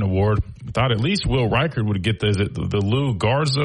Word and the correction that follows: Lou Groza: Award or however you pronounce it. – Lou Groza. – Yeah, sorry. Lou Groza.